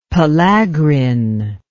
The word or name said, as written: pellagrin